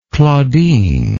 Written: claudine

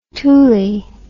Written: toolie

